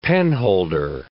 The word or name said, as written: penholder